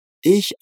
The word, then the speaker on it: ich: O primeiro do ich, ele é bem contido, aquele CH fraquinho, que a gente faz só com o movimento da língua.